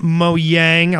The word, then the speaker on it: Mojang: Mojang, I'll even pronounce it right this time, for making the company what it is today.